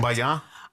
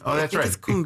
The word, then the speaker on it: cumbia: And then on the 16th, there is cumbia. I hope I'm pronouncing that correct. – Cumbaya? – No, I think it's cumbia. – Cumbia. Yeah, it's cumbia.